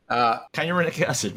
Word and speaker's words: kynurenic acid: kinurate, I don't know if I'm pronouncing it right. I was reading, I don't know. Pronounce it. – Kynurenic acid, right.